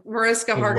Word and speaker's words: Mariska Hargitay: So she was applying lip gloss during Mariska Hargitay's People's Choice Award speech. I think I butchered the No, you actually name. You got it. Mariska Hargitay.